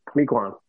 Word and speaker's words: Mequon: Did we say the name right? Mequon? – Mequon.